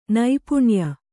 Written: ನೈಪುಣ್ಯ

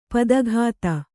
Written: ಪದಾಘಾತ

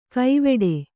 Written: ಕೈವೆಡೆ

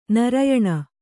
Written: ನಾರಾಯಣ

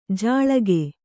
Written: ಜಳಿಕ

